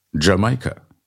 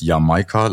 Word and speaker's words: Jamaika: Sagen nicht alle Jamaika? – Wenn, dann konsequent Englisch Jamaika. Ansonsten ist Jamaika laut Duden im Deutschen die einzige Aussprachemöglichkeit. Also,